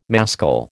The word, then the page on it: mascle: American English